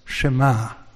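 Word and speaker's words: Shema (שמע): This next one is a Hebrew word, and it's Shema, with an emphasis on the A, Shema.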